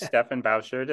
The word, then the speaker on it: Stefan Bauschard: So my name, you know, as you pronounced correctly, my name is Stefan Bauschard.